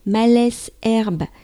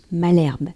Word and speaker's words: Malesherbes: Je suis descendue à la station Malesherbes, celle que Lisa aime prononcer Malesherbe, ou que d'autres personnes prononcent Malherbe. Bon, j'ai toujours entendu Malesherbe dans la bouche de ma mère, qui a vécu toute son enfance dans le 17e.